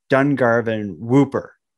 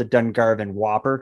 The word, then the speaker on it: Dungarvan Whooper: There's a famous, it's like New Brunswick's most famous ghost story is the story of the Dungarvan Whooper. Some people call it the Dungarvan Whopper because it's spelled the same way as the burger, but Whooper is the right pronunciation.